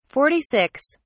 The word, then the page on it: 46: British English